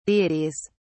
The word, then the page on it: deities: British English